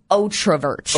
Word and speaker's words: otrovert: Would an outrovert, am I saying it right? Outrovert? – I think it's otrovert. – Otrovert. – Like O-T-R-O.